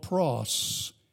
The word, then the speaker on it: praus: He used a word in Greek that's called pros. P-R-A-U-S is how we would spell it in English.